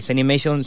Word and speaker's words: Zenimation: Se llama Zenimation, O, sí, Zenimation sería la pronunciación, pero es como una mezcla entre eh, la cultura zen y la animación.